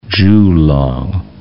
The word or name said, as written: Jiulong